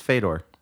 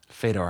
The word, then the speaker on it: Fyodor: I think that's pronounced Fedor. – Fedor, I think you're right.